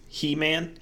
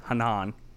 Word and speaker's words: Henan: Did you say He-Man? He-Man province? – Yes. H-E-N-A-N. Did I pronounce it right? Or is it Hanan? – Oh, I thought you said He-Man. – No. – Like He-Man, like the action character.